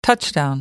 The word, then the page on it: touchdown: American English